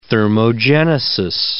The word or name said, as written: thermogenesis